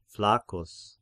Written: Flaccus

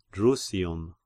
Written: Drusium

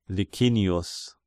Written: Licinius

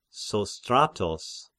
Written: Sostratos

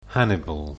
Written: Hannibal

